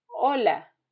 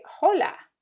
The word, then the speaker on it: hola: The letter H has no sound in Spanish. For example, hola, hola. Most English speakers sometimes can't help old habits and they will say hola. Just try and remember that H is silent.